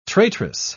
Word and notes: traitress: British English

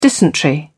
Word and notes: dysentery: British English

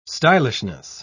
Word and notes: stylishness: British English

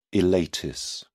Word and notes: ileitis: British English

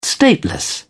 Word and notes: stateless: British English

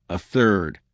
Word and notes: a third: American English

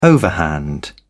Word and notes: overhand: British English

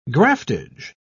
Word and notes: graftage: British English

